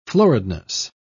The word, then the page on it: floridness: British English